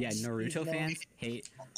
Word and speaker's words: Naruto: Naruto is just you putting the stress on the wrong part of the word. I think that's it.